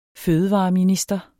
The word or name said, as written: fødevareminister